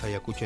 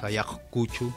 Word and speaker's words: Ayacucho: Hay un montón de pueblos como Ayacucho, que en realidad no es Ayacucho, es Ayacuchu. – Ah, mirá vos. – Y ahora se dice Ayacucho.